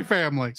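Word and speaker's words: Spy × Family: Spy Family. – Spy Family. – The X is silent.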